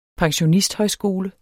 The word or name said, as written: pensionisthøjskole